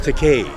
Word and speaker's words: Takei: It's pronounced Takei.